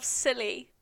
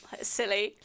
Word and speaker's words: Scilly: But he chose for her husband Baron Robert of Scilly. That is spelled Scilly. – Wait, is it spelled like Scilly? – Yeah.